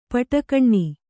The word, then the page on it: ಪಟಕಣ್ಣಿ: paṭa kaṇṇi